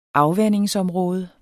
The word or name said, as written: afvandingsområde